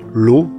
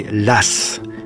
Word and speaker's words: Law: Précisons que les Français de l'époque ne prononcent pas son nom l'eau, mais l'as, comme si c'était deux S.